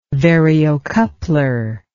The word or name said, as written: variocoupler